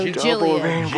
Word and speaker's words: Gilead: Fender, Clever, and I think that's Gilead from Handmaid's Tale. – No, Gilead. – Gilead. – G-I-L-E-A-D.